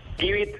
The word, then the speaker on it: Gibeet: Givit, eh, se pronuncia give La palabra en inglés, la frase give de Dalo,